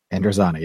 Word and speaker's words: Androzani: Androzani? – Androzani, yes.